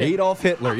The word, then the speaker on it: Adolf Hitler: Is that the right pronunciation? – Adolf Hitler, yes.